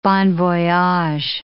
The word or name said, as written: bon voyage